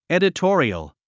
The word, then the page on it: editorial: èdətɔ́ːriəl; エデトォリョォ